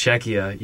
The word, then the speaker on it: Czechia: Okay, this country I can never Czechia. – Pronounce. Czechia, yeah, like crazy spelling.